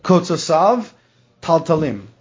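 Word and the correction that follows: Kivutzosav Taltalim (קווצותיו תלתלים): Oh, Kivutzosav Taltalim. That's the way to pronounce it. Kivutzosav Taltalim.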